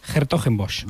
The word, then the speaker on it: Hertogenbosch: Hertogenbosch.